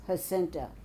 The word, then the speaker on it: Jacinta: The J is pronounced like H's. Jacinta, but it okay.